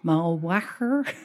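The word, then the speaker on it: Mauchline: That's, no, you have to pronounce the word. – Mulwarker. – Yes. Say it again. – Mulwacher.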